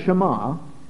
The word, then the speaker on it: Shema: The Jews called it the Shema, spelled S-H-E-M-A, pronounced Shema.